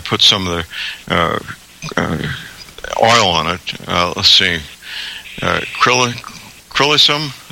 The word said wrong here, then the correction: helichrysum: is that, am I saying it right? – Yeah, helichrysum. – Yes. Helichrysum.